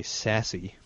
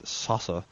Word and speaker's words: Sasse: And Hermann Sasse, you know, Hermann, you could say sassy, probably, but I prefer to pronounce that Sasse, said that the church still survives because every day she prays, Lord, keep us steadfast in thy word.